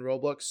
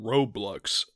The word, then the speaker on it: Roblox: And you've been saying Roblox, like with a U this entire time.